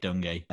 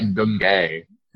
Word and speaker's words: Dungy: Tony Dungy, no relation to our good friend Eric Dungy. – Tony is pronounced Dungy. – Yeah. – I thought you were like Dungay.